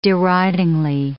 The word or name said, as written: deridingly